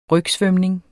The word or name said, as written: rygsvømning